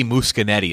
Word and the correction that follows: Muschietti: Is that how you say his name? – Muschietti.